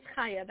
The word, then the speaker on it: Chaya: Sure, my name is Kaya. That's spelled C-H-A-Y-A.